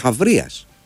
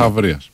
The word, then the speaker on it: Χαβρίας: Τσάμπριας. Χάμπριας. Δεν ξέρω πώς ακριβώς διαβάζεται αυτό. Και τι είναι ακριβώς. Είναι, α, συγγνώμη, Χαβρίας. Αθηναίος στρατηγός του 4ου αιώνα. – Χαβρίας.